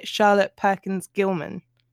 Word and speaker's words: Charlotte Perkins Gilman: We will be discussing The Yellow Wallpaper by Charlotte Perkins Gilman.